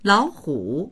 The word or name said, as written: lao3hu3